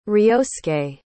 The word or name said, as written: ryosuke